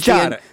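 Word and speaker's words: Chiara: I do think it's funny when Sierra, he calls her Chara, which is Chara, the Italian pronunciation.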